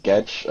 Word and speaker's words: Guedes: Can we get a Portuguese pronunciation on those? – It is Nunes and it's Gedge, I think.